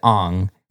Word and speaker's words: Aang: There was a character who is named Aang, spelled the same way, but they pronounced it Ong because that's the correct...